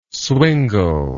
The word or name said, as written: swingle